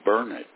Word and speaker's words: Burnet: I think the city that you were talking about, that you have a grandson living there, is Burnet, B-U-R-N-E-T, unless you're talking about Burt Burnett, which is in a different part of the state.